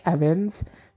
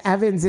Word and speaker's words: Evans: My at name is Dominic Evans. That's spelled D-O-M-I-N-I-C-K. And then Evans is just Evans, but I'll spell it just in case. E-V-A-N-S.